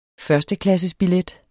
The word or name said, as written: førsteklassesbillet